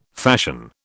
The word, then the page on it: fashion: British English